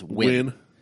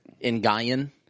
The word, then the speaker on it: Nguyen: It's just XI, but it's like pronouncing NGUYEN is win. In Guyan. – Yeah. – I call it in Guyan. They're like, no, it's win.